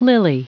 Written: lily

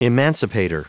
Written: emancipator

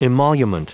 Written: emolument

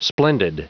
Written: splendid

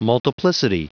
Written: multiplicity

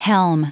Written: helm

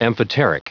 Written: amphoteric

